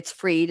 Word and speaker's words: Fried: And the voice of Brian you just heard, it's Brian Freed, and I think he and I have a running joke that Freed is spelled like fried, but it's Freed, it's pronounced.